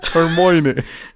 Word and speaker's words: Hermione: Hermione.